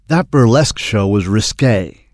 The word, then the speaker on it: risqué: Se pronuncia risqué.